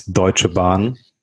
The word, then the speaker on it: Deutsche Bahn: the first company you mentioned is deutsche bahn but I, I, think you pronounced it very very good so um, i think that's just...